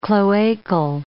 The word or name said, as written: cloacal